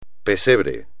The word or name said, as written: pesebre